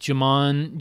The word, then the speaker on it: Djimon: How do you pronounce his name? It's DJIMON. – Oh, like Jimon.